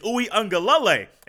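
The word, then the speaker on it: Uiagalelei: And I said, Lele, because I watched a YouTube video, and whoever was there said, Lele. And she's like, no, it's Ui Ungalale.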